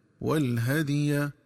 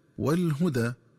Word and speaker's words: والهدي: ينبغي التنبه للنطق الصحيح لكلمة والهدي، فتقرأ بفتح الهاء وتسكين الدال ثم ياء مفتوحة هكذا والهدي، ومن الخطأ قراءتها بضم الهاء وفتح الدال ثم ألف مقصورة هكذا والهدى.